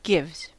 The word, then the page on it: gives: American English